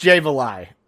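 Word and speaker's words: Jay Valai: It is pronounced Jay Valet. So we're going to pronounce it Jay Valet.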